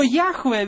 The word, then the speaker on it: Яхве: Поэтому большинство сходится на мнении, что это должно произноситься как Яхве. Но где-то, на какой-то момент возникло произношение Иегова или Яхова. И оно стало довольно распространенным. Но Яхве, вероятнее всего, является наиболее правильным или более близким к истинному произношению, хотя точно этого никто не знает.